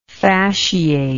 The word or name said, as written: fasciate